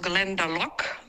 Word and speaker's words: Glendalough: Ähm, ich komme frisch aus Irland und den Whisky, den du meinst, äh, Carsten kommt aus Glendalough, so wird der Ort ausgesprochen. Ich war tatsächlich da. Ähm, und der kommt nicht aus Schottland, sondern aus Irland aus Glendalock.